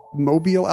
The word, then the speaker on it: Mobile: We also got a note from Chad in Mobile, Alabama. I said that wrong before. – Mobile.